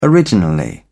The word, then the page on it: originally: British English